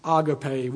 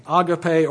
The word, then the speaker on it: agape: But in many places in the Bible, we're talking about what sometimes we might say agape. We've heard it pronounced that way. Agape or agape.